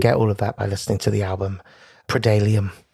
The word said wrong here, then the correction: Preludium: Oh, I think I'm pronouncing that? – Preludium. – Preludium. – It's definitely a U in the middle there.